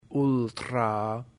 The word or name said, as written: ultra